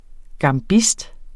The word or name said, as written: gambist